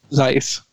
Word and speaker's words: Zeiss: Zeiss.